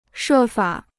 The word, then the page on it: 设法: shè fǎ